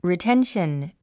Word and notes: retention: riténʃən; リィテンション

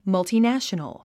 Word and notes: multinational: mʌ̀ltinǽʃənəl; マゥチナショノォ

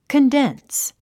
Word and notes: condense: kəndéns; カンデンス

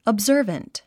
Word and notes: observant: əbzə́ːrvənt; オブザァバント

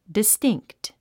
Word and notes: distinct: distíŋkt; ディスティンクト

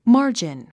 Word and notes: margin: mάː r dʒin; マージン

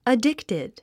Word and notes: addicted: ədíktid; アディクテッド

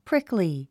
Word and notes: prickly: príkli; プリクリィ

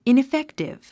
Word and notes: ineffective: ìniféktiv; イネフェクティブ

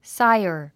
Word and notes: sire: sáiər; サイアー

